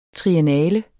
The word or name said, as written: triennale